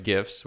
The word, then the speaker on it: GIF: Yes, that's right, GIFs with a hard G.